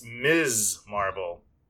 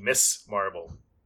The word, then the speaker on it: Ms. Marvel: So first off, it's Ms. Marvel. I don't know why, but I kept saying Miss Marvel. But it's not Miss. It's Ms. because it's M-S dot, M-S period, which is pronounced Ms. Just, I want to clarify that because I was calling it the wrong thing all this time.